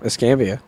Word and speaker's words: Escambia: Did I say that right? Escambia? – Yeah, Escambia.